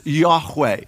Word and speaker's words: Yahweh: So scholars think that it's pronounced Yahweh.